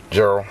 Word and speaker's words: Gerald: Gerald Harrison. G-E-R-A-L-D. H-A-R-R-I-S-O-N.